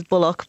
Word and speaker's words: Bullough: The Irish pronunciation is Bullock, but he pronounces it Bullough.